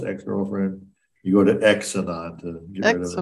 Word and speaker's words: Xenon: X-E-N-O-N. X-non. – X-E Xenon. Xenon. – Xenon and Xenon. – Xenon is probably pronounced as Z. It's generally pronounced as Z. – Yeah, it's usually pronounced like a Z.